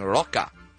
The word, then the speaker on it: De La Rocha: I was saying Rocha. Apparently it's right. Rocha.